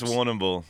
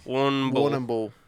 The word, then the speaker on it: Warrnambool: I think it's Warrnambool. – Warrnambool. – It is.